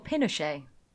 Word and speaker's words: Pinochet: Yep, the government is overthrown by General Pinochet.